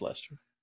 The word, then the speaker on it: Leicester: That's how you say it. – Leicester? – Yeah, but it's called Leicester. – I don't know. – It's Leicester.